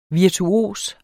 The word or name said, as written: virtuos